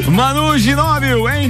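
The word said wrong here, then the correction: Ginóbili: É essa pronúncia mesmo? Isso. É, Ginobili.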